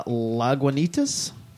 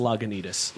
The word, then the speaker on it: Lagunitas: I'm going to pronounce this wrong, but we're drinking Something Easy by Lagunitas. It's a Something Easy Ale. – Close enough. I think it's Lagunitas.